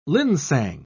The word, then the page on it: linsang: British English